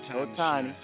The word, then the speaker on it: Ohtani: Ohtani is that he needs to learn English if he's going to be the face of Major League Baseball.